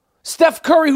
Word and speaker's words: Curry: Steph Curry, whose last name rhymes with Flurry.